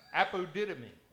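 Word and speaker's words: apodidomi (ἀποδίδωμι): Apodidomi. It is, apodidomi. Well, I did it phonically so I'd pronounce it right. It's apodidomi.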